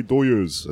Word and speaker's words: Dodgers: These are my doyers. That's how you say it if you're Mexican in LA.